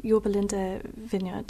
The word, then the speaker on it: Yorba Linda: So Chrissy, you planted and led the Yorba Linda Vineyard.